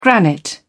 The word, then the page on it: granite: British English